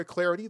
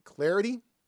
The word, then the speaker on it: Clardy: Hit me up on Twitter at Troy Clarity. The last name is spelled C-L-A-R-D-Y, at Troy Clarity.